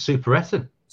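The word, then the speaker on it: Superettan: Can you give me the pronunciation of that so I know that for future? – You nailed it. I think super Etten.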